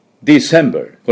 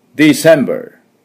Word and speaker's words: December: で、 D の音はですね、工事現場で穴を掘るドリルの音、トゥトゥトゥトゥトゥトゥ、しっかり鋭く、ディセンバーというように弱くやらないように、トゥトゥトゥ、ディセンバー、ディセンバー、これぐらい、トゥトゥトゥ、ディセンバー。で、セのとこにアクセントがあります。そこ注意ですね。で、バー、最後 R、ちょっと下を引いてください。ね。お風呂に入った時の、アー、こんな感じですね。で、私の続いて発音、いきますよ。ディセンバー。 December。